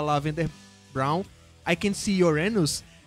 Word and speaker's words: Uranus: É Uranus. Quem não sabe, né? Em, em Urano em inglês é Uranus, que é a mesma pronúncia de seu anos.